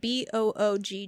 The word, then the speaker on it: Böögg: Böögg is spelled B O O G G, like boog.